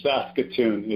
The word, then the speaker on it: Saskatoon: It's Saskatoon is how you say that.